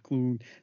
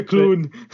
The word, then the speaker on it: Clune: Clown, Clune. Sounds like someone from Hull saying clown. – Yeah. Yeah, Clune.